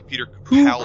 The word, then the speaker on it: Capaldi: By the way, I guess we're saying it wrong. It's Peter Capaldi.